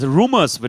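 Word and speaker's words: Rumours: Rumors wird das ausgesprochen. Das Album wird Rumors ausgesprochen.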